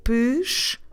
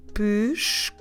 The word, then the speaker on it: pescoço: We spell it P-E-S-C-O, C de cedilha, O. And if I go very slowly, I say pescoço. Very slowly, pescoço. Coço. I'm stressing the middle syllable there, the second to last, co. Push, co, su. But when we say it rapidly, we say pescoço, dropping completely that letter E on the first syllable.